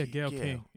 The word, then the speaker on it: Gail King: Yeah, Gail King.